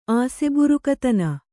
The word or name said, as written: ಆಸೆಬುರುಕತನ